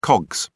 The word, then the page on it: cogs: British English